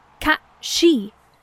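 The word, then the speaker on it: Cat Sìth: I got one here that says Cat City. Am I pronouncing that correct? Cat City? – That ain't a city I ever want to visit. – Cat She. And sure, this is the Irish variation.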